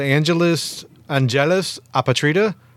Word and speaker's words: Angelus Apatrida: They're called Angelus Apatrida. – That was absolutely perfect pronunciation.